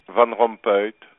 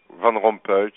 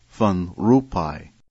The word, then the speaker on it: Van Rompuy: Herman Van Rompuy. – But VOA's Rompuy gets the thumbs down from one Dutch speaker, Geert de Proost, the representative of the Flemish government in the United Kingdom.